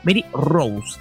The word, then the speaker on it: Rous: R-O-U-S, no es como Rose, así Rose, sino Mary Rose,